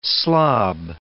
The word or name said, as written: slob